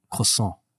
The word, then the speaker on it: croissant: You're saying it wrong. – How do you say? – Croissant.